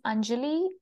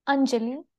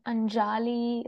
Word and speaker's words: Anjai: So my name is Anjai, and it's Anjai. It's not Anjali. It's not Anjali. It's not some people call me Anjali.